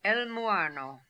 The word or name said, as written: Elmooarno